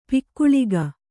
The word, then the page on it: ಪೊಗಳಿಕೆ: kannada